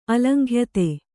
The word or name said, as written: ಅಲಂಘ್ಯತೆ